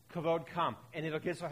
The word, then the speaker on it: kavod: The Hebrew word for glory is kavod. Some people try to pronounce it kabod. That's not correct. It's kavod.